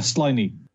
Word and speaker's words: Sliney: Sibley? – Uh, Sliney. – Say it again. – Uh, slimy, like slimy, but like slimy.